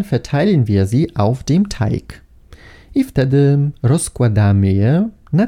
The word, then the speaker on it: Teig: Nie czytamy go jako Teich, ponieważ to słowo piszemy przez CH i oznacza staw. Teig piszemy przez G i tak też je wymawiamy.